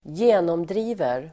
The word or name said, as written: genomdriver